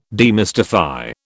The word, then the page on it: demystify: British English